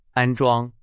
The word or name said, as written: 安装